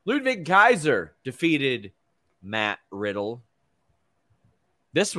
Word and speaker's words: Ludwig: Ludwig. – Yeah, but it's, yeah, with a W, so it throws me up, and I want to say, yes, Wig instead of Vig.